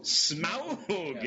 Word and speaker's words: Smaug: Smaug.